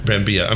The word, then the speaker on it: Brambilla: Then they ended up getting this director, Marco Brambilla. I mean, am I saying that right? – Brambilla.